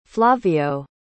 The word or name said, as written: flavio